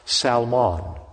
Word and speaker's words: Salmon: And his name sounds like the fish, salmon, S-A-L-M-O-N. I believe it's pronounced Salmon. She married a Jewish fellow by the name of Salmon.